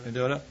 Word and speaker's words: Mendota: Fresno has already launched in April at three locations: Fresno, Mendota. Am I saying that right, Judge Justice Baxter? – Mendota, and Coalinga.